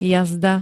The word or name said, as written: jazda